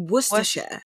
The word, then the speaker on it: Worcestershire: Now, before, I couldn't even pronounce Worcestershire. We were just like, was it Worcester? all of the things. And then we then found out, oh, it's Worcestershire. Or Worcestershire. – Isn't it Worcestershire? – Like Worcestershire. Worcester. – It's an R. – So it's an R, but like it depends on how you pronounce it. Some people say Worcester, some people say Worcester.